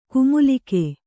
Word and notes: ಕುಮುಲಿಕೆ: kumulike

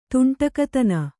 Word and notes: ದಾಂಡಿಗತನ: dāṇḍigatana

